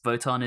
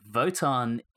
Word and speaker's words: Wotan: So in Norse mythology, Votan is another name for Odin. So presumably the Norse aspect is why Votan with a W is pronounced as if it has a V.